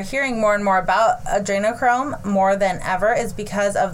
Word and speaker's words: adrenochrome: Because I say adenochrome, no problem, but adrenochrome is how you say it. Just think adrenaline.